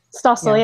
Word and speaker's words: Stossel: Stossel, yeah.